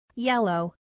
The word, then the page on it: yellow: female